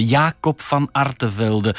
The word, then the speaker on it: Jacob van Artevelde: Jacob van Artevelde, un illustre nom gravé dans le marbre de notre histoire nationale.